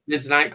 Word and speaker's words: Bentonite: Bentonite clay? – Bentonite clay, yeah.